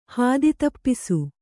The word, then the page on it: ಹಾದಿತಪ್ಪಿಸು: Kannada